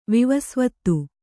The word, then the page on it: ವಿವಸ್ವತ್ತು: vivasvattu